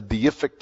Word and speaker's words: deific: That's a deific characteristic. Are you impressed that I got that right? I wanted to put another syllable in there, but I took my time and got it right.